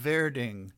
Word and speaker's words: Werding: And the girl who sings it was Juliane Werding. Okay, you spell it W-E-R-D-I-N-G. I guess in German, W's are pronounced like V's. Werding.